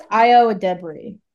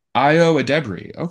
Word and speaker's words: Ayo Edebiri: I think it's Ayo Edebiri. – Ayo Edebiri.